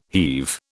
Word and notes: heave: British English

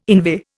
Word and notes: inveigh: Indian

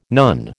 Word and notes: nun: American English

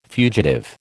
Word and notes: fugitive: American English

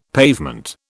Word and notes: pavement: British English

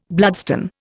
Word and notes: bloodstone: Indian

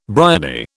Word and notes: bryony: British English

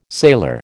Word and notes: sailor: American English

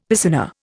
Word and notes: piscina: Indian

